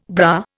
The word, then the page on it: bra: Indian